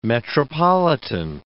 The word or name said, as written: metropolitan